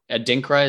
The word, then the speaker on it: Adinkra: But so actually on the chocolate bars themselves, you have these, and Adinkra, is that how you pronounce it? – That's but correct.